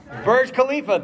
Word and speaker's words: Burj Khalifa: Burj Khalifa, thank you.